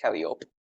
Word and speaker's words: Calliope: And It's pronounced Calliope.